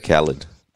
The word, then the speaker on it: Khaled: Birdie reckons that you're DJ Khaled.